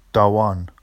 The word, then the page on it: dɑwɑn: Vafsi